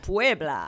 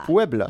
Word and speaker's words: Puebla: Désolé si je le prononce pas comme du monde, mais non, c'est bon, Puebla. – Puebla, c'est ça.